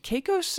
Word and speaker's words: Caicos: On June 29 of that year, they, on the island of Turks and Caicos, is that yeah. – pronounced correctly? Okay.